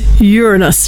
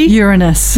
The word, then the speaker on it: Uranus: So it's Uranus. You ready? – Uranus.